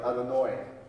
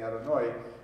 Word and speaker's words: Adonai: Until you get to some interesting derivations like Adonoi, which I guess the oi is a, can be, it's a more Yiddish pronunciation, but there is the Spanish way of saying Adonoi.